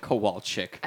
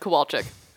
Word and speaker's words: Kowalczyk: Is that how it's pronounced? – Kowalczyk. – Kowalczyk. – Ed Kowalczyk.